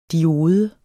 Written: diode

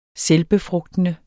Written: selvbefrugtende

